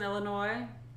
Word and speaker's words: Illinois: Whoever won that thing in Illinois, you better be buying an Italian castle. – Do you hear him not pronouncing the S in Illinois? – Illinois.